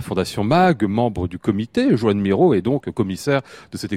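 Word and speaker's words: Joan Miró: Joanne Miro, c'est bien comme ça qu'il faut prononcer, m'avez-vous dit euh, juste euh, à l'instant.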